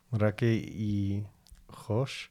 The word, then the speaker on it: Drake y Josh: Drake y Josh.